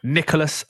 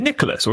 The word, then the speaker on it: Nicolas: Is it Nicholas or is it Nicola? – Oh, sorry. – Or is it just pronounced Nicola? – Ami, how good was Nicolas Anelka? Yeah, in French you don't pronounce that. In French you don't pronounce the last letters.